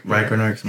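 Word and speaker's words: Ragnarök: Ragnarok.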